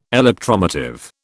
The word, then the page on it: electromotive: British English